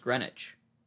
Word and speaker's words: Greenwich: I think it's actually pronounced Greenwich.